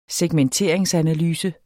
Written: segmenteringsanalyse

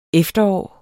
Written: efterår

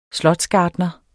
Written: slotsgartner

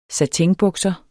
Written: satinbukser

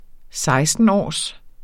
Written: sekstenårs

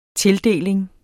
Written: tildeling

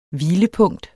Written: hvilepunkt

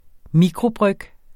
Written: mikrobryg

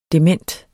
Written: dement

